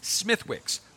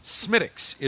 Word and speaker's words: Smithwick's: Now, it's spelled S-M-I-T-H-W-I-C-K apostrophe S, Smithwick's, but it's not. Smittix is the correct pronunciation.